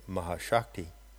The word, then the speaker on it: Mahashakti: I also refer to the term Mahashakti. And in one instance, I pronounce it Maya Shakti. So Maha Shakti, which means the great power, or Maya Shakti, which means the great power that produces the apparition of the universe.